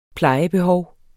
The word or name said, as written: plejebehov